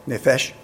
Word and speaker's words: nefesh: So you see the word nefesh there, N-E-P-E-S, the P is pronounced like an F, nefesh.